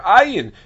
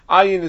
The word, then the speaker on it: Ayin: What we do when we pronounce them exactly the same way, Ayin is a little more guttural. It's a little closer to a gimel in a certain sense, whereas Aleph is just a flat kind of sound.